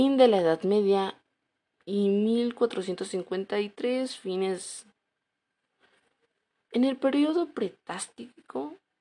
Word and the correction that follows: patrístico: lo siento, patrístico, Disculpen si no los pronuncio un poco bien.